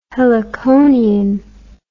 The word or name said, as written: heliconian